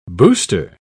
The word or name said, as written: booster